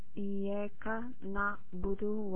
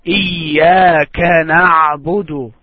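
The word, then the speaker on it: Iyyaka na'budu (إياك نعبد): We have two letters in Arabic language, ka and qa. So when you pronounce ka, try to make it ka. Iyyaka na'budu.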